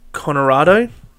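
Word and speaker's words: Coronado: Conorado.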